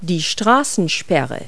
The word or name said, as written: barrier